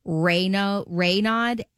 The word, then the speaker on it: Raynaud: I'll pronounce it wrong, but it's called like Raynaud. It's R-A-Y-N-A-U-D, Raynaud's.